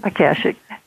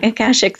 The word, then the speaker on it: Akashic: Through her writing, her consulting, her teaching, and Ash, Ash, can you help me with that? I'm I'm blanking on that word, the pronunciation. Akashic Akashic, thank you.